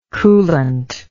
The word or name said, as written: coolant